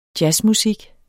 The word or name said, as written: jazzmusik